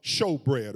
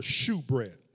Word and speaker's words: shewbread: Now, as you move in, you will see the table of showbread, or shewbread, as some pronounce it, the table of showbread.